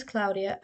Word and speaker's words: Claudia: claudia